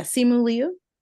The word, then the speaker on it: Simu Liu: Simu Liu Did I pronounce that right? I actually don't know. – Yeah, Simu Liu.